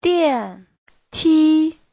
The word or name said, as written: dian4ti1